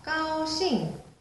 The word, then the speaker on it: 高兴: 高兴，일성사성에 다시 해볼게요. – 高兴。